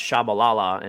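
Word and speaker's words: Tshabalala: Flute screamer from outside the box from a player whose name's pronounced Shabalala, and it was went down in history just because of the commentator.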